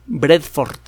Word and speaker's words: Vredefort: Ahora se le conoce como el cráter de Bredford. Se escribe V-R-E-D-E-F-O-R-T. Bredford. E o Bredford.